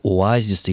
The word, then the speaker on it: оазис: И, например, до сих пор орфоэпические словари рекомендуют говорить «оазис» или «барокко», «рококо» и так далее, «маэстро», допустим.